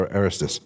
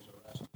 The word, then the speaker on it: Erastus: How is it Erastus or Aristus? – My self-pronouncing Bible says Erastus. Erastus, all right.